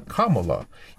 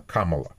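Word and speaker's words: Kamala: И она специально оговаривает, что она Камала. И республиканцы... Да, извините, все, я возвращаюсь, но республиканцы специально местные, говорят, они ударение неправильно ставят.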